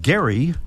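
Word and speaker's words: Gerry: And thank you to Jeannie Dana from Gary, New York, as in gerrymandering, which they tell me is actually the correct pronunciation, but more on that later.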